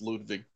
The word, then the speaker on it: Ludwig: Well, it, depending on who pronounces it, you're probably going to hear it both ways, but W is pronounced with a V. It's Ludwig. Okay.